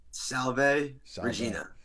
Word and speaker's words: Salve Regina: Salve, salve, Regina.